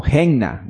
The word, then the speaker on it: Gehenna: Pues la palabra infierno viene de la voz de origen hebrea, que significa, o se pronuncia como Gehenna, que no es otra cosa que una traducción que hace referencia a el valle del Hinón.